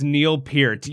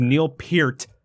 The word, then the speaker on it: Neil Peart: You know, that's a hill that I'm gonna die on because I gotta respect that man's name by pronouncing it correctly, as do everybody else. So you know, it is Neil Peart, ladies and gentlemen. That is what it is.